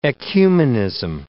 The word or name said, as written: ecumenism